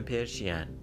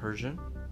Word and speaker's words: Persian: این پرشین که تلفظش رو که ما چک کردیم پس این از نظر تلفظی که کاملا غلطه اصلا هیچ ربطی به انگلیسی نداره چون اگر تلفظ آمریکای شمالی بخوام بکنم بعد بگن I'm Persian اگر بخوان تلفظ بریتانیایی داشته باشن میگن I'm Passion دیگه ما شکل دیگری نداریم برای این کلمه